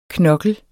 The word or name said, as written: knokkel